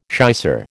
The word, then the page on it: shicer: American English